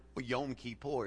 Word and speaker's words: Yom Kippur: I said, well, it's actually not Yom Kippur. It's pronounced Yom Kippur. He said, what does Yom Kippur mean? I said, well, you just introduced me to talk about an ocean of fish. So it's called Yom Kippur.